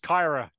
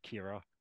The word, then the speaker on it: Kyra: It's pronounced Kyra Cooney-Cross, not Kira.